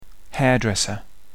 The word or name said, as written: hairdresser